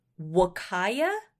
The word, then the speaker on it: Huacaya: I'll just tell you, it's Huacaya. I always called it like Huacaya while I was working on the project. Nobody corrected me. I only recently went and looked up how do you pronounce this word. – And it's Wakaya? – Yeah. I like to think of it as a small child trying to say Wakanda forever.